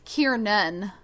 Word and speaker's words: Kiernan: Kieran Shipka? Is that right? – I think it's Kiernan. – Kiernan. Kiernan. Kiernan. Okay. So, Kiernan Shipka. – Kiernan Shipka. It plays Catherine. Kiernan is best known now for being Sabrina Spellman in the Chilling mm-hmm.